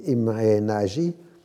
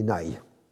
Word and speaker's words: Nagy: Alors en français, on dit Imre Nagy, et en hongrois, on dit Nagy. J'ai regardé ça sur Google pour la bonne prononciation.